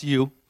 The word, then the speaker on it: ewe: Well, it's actually spelled E-W-E, no correlation, but it's pronounced U.